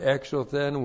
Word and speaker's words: exothen: This is the adverb exothen with the nominative plural of makai, plus the adverb esothen with the nominative plural of phobos. Okay, exothen. Looks like that. E-K-S, long O-T-H-E-N. Exothen.